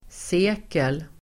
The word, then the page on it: sekel: [s'e:kel]